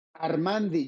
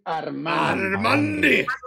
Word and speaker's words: ARmandi: So you say ARmandi, because I have been saying ARmandi this entire time. I'm mispronouncing that. – ARmandi, you need to say ARmandi. – ARmandi. – ARmandi. – You gotta roll the R.